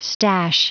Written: stash

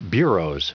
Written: bureaux

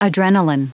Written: adrenalin

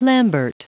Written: lambert

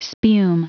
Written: spume

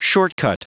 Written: shortcut

